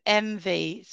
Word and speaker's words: Emvi: It's called MV. So it's spelt EMVI.